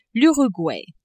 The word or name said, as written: Uruguay